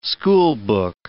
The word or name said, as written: schoolbook